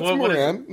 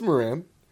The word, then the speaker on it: Moran: Moran. It's Moran. – No, it's not. You're looking at me like it's wrong. – No. Absolutely. – Oh, okay. – You put a lot of emphasis on the mo.